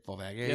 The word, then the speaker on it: Volvagia: Um, Femi was uh, I'm going to totally butcher his name right now, but uh, Volvagia. No, that's right. Volvagia, yep.